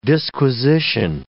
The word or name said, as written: disquisition